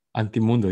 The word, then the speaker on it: anti-mundo: Am I pronouncing that properly? – Anti-mundo, yes.